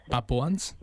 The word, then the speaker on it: Papuans: Now, in regards to the indigenous Papuans I'm just trying to make sure I pronounce that word correctly. – Papua. – It's Papuans. – Yeah. Yes.